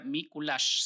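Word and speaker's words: Mikuláš: Ok, ok, Nicolás se escribe M-I. C-U-L-A-X, para los que no sepan, es eh, digamos, y se pronuncia Mikulash, si no estoy mal.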